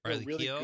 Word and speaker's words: Riley Keough: Riley Keough.